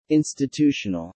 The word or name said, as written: institutional